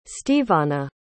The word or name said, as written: steveanna